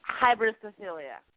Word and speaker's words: Hybristophilia: Did I pronounce it correctly? – Hybristophilia. Yeah.